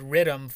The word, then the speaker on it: riddim: Uh, due to the Jamaican influence, there's a subgenre called riddim, for example, which is like a, a you know Jamaican pronunciation of rhythm,